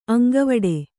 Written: ಅಂಗವಡಿ

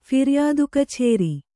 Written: ಫಿರ್ಯಾದುಕಛೇರಿ

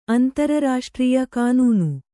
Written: ಅಂತರರಾಷ್ಟ್ರೀಯ ಕಾನೂನು

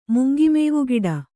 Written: ಮುಂಗಿಮೇವುಗಿಡ

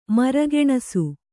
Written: ಮರಗೆಣಸು

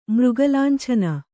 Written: ಮೃಗಲಾಂಛನ

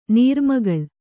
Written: ನೀರ್ಮಗಳ್

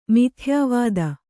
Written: ಮಿಥ್ಯಾವಾದಿ

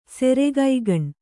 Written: ಸೆರೆಗೈಗಣ್

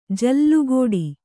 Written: ಜಲಘಡಿ

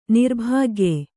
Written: ನಿರ್ಭಾಗ್ಯ

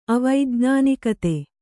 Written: ಅವೈಜ್ಞಾನಿಕತೆ

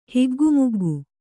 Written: ಹಿಗ್ಗುಮುಗ್ಗು

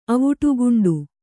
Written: ಅವುಟುಗುಂಡು

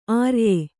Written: ಅರಿಯ

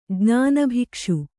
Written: ಜ್ಞಾನಭಿಕ್ಷು

